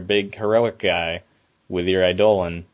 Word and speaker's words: Eidolon: That's how you pronounce it. Eidolon.